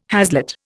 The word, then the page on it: haslet: Indian